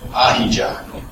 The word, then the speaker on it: Ahijah: So he sent a word to a prophet, Ahijah.